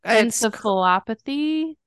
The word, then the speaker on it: encephalopathy: Encephalopathy.